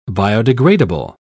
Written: biodegradable

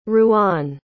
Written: Rouen

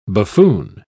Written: buffoon

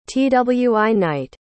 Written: twi-night

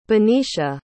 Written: benicia

